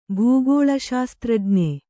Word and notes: ಭೂಗೋಳಶಾಸ್ತ್ರಜ್ಞ: bhū gōḷa śastrajña; kannada

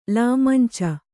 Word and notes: ಲಾಮಂಚ: lāmanca

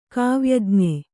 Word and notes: ಕಾವ್ಯಜ್ಞ: kāvyaj'na

